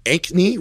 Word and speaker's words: Ankney: It's Ankney, right? – Correct.